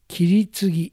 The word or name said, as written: Kiritsugi